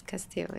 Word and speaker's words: Castiel: Because I think it's Castiel. – Yeah, it's Castiel, yeah.